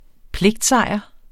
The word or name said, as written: pligtsejr